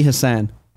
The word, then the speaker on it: Hassan: The Federal Trade Commission today agreed to investigate video game loot boxes following an official request by Senator Maggie Hansen, a Democrat from New Hampshire. – Maggie Hassan. – Damn, thank you very much. I did totally butcher that.